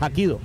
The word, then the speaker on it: Hapkido: Parkido, esta no, Hapkido, dije Hapkido, está bien que yo soy mula, pero sí sé que es Hapkido. Yo, es más, yo participé Hapkido, yo soy Hakimate.